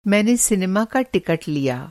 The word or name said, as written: टिकट